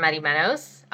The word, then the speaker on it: Madimenos: You can find me on Instagram at Barbara Madimenos, spelled B-A-R-B-A-R-A-M-A-D-I-M-E-N-O-S.